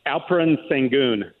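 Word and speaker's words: Alperen Şengün: And Alperen Sengun is how you say his name.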